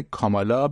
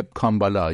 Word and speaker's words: کامالا: اما فعلا از هریس چیزی نداره، یا میگه او کمونیسته یا با بد تلفظ کردن کامالا به طور عمدی اسمش رو مسخره میکنه، میگه کامبالا یا کامابالا یا کارامالا. خب برای مخاطبانش سرگرم کننده است.